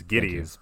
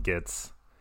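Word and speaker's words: Gittes: It's Giddies, but they call him Gitz.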